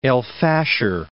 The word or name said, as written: El Fasher